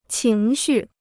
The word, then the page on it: 情绪: qíng xù